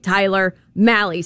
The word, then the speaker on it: Malley: It rhymes with Alley. He's been here long enough. Get the man's name right. Tyler Malley.